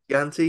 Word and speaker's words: Chianti: Oh, I never know how to say this one. – Chianti. – Chianti, yeah, I think you're right.